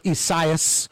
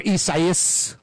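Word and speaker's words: Isaias: Anyway, we'll keep you up to date on Tropical Storm Esaias, or Esaias, three or four syllables. No, I literally went online. I mean, I was pronouncing it Esaias. But I went online and there were, you know, the pronunciation. They had Latinos saying Esaias and some saying Esaias. I think that Eus part at the end is said quickly, where it's almost three syllables, not four.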